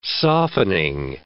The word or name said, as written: softening